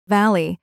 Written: valley

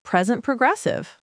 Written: present progressive

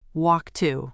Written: walk to

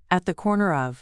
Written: at the corner of

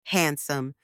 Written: handsome

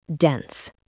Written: dense

